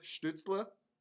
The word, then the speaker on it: Stützle: I think with who the Sens drafted as well, especially with Stützle, I think. – Great pronunciation. – Thanks, man. – I know you were kind of looking at me like, yeah, I did it. – Was I German enough for you?